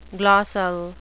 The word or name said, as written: glossal